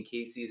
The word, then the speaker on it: Cayce: So right now my website is Coaching with Casey, and Casey is spelled C-A-Y-C-E.